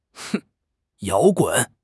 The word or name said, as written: 摇滚